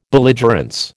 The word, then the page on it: belligerence: American English